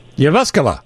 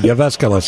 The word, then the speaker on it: Jyväskylä: Okay, it is Jyvaskyla. – Jyvaskyla. – There you go. – Yeah, that's it. We'll go with that. Yeah. – I grew up around Finlanders. I think the pronunciation's close. I don't know if I've got it, but Jyväskylä sounds right to me. – Yeah, that actually, because it is a soft J in the Finnish language.